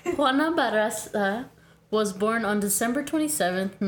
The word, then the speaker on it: Juana Barraza: Can't roll my R's, but it sounded good. – It's enough. – Better. It's better than me and Michelle would have pronounced it. – Try it. – Can I see how it's spelled? Okay. Juana Barraza. – That's pretty good.